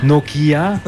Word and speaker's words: Nokia: Mais il n'y a pas, faut pas accentuer la prononciation de chaque lettre. – Non. – Nokia.